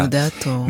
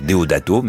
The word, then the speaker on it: Deodato: Deodato. – Mais et Deodato, voilà. Moi, j'allais dire Deodato, mais Deodato.